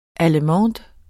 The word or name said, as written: tysk